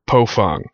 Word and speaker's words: Baofeng: The cheapest of them being the Baofeng, or Pofang, B A O F N G uh, apparently is supposed to be pronounced as P O F U N G. Uh, the company's actually changed the name so that us Americans pronounce it the right way.